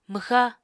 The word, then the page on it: mwxa: Apatani